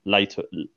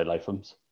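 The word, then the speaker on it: Lathums: And Cass Stevens said, I always say Lathum's, but Liam Fray asked them on a live vid and they said Latums. Lathum's. – I think I knew that and have ignored that as well. Yeah. I think I did know that. Yeah. Just things that get pronounced wrong just generally annoys me. – I'll still I'll still say Lathum's.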